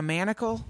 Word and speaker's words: manacle: If you're talking about games or anything, it's a manticle of sorts. – You mean a manacle? – Yeah, a manacle. – I thought you said manticle. – I think I did. I think I said it wrong.